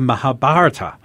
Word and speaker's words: Mahabharata: Sanskrit epic called the Mahabharata.